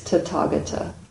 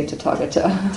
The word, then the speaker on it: Tathagata: Everything is pronounced as T. Don't be confused by the H in the middle. – Okay. – It's probably slightly different in correct Pali, but we say Tathagata.